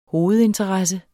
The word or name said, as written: hovedinteresse